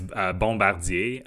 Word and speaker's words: Bombardier: So it's Bombardier. That's how we pronounce it in French.